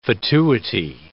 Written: fatuity

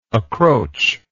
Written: accroach

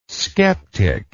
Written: sceptic

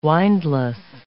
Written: windless

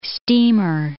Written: steamer

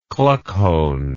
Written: kluckhohn

